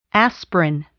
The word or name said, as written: aspirin